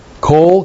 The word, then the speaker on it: kol: And then the noun kol, kol, K-O-L, means all or the whole of, literally.